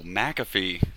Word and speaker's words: McAfee: Oh, McAfee. See, McAfee. You pronounced it correctly on the last podcast when you mentioned me. But I'm a proper Scotsman, so. – I see. – Thanks. Not a misspelled Irishman. It's not McCarthy.